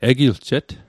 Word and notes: egiljet: Mi'gmaq

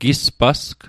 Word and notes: gispasg: Mi'gmaq

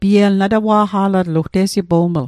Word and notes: natawa'qa'latl: Mi'gmaq

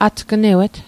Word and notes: atgnewet: Mi'gmaq

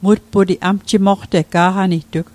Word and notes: amjimoqteg: Mi'gmaq

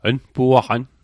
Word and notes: npuaqan: Mi'gmaq

